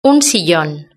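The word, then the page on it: un sillon: Spanish (Spain)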